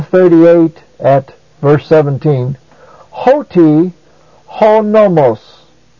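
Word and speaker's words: honomos (ὁ νόμος): ho also is a proclitic, so it is pronounced with the word nomos, so it's hoti honomos.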